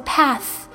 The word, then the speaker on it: path: 好，这个 path 我们要特别注意一下它的发音， th 是发这个咬舌音，就是说不触碰声带的这个咬舌音，它是 th，